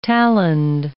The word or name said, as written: taloned